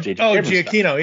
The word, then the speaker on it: Giacchino: Giacchino, Giacchino does J.J.